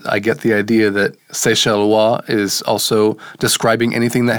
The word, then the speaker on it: Seychellois: And we see here there's a variety of Seychellois. That O-I-S on the end of a French word is often pronounced wa.